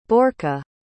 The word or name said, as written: borka